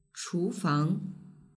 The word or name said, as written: chufang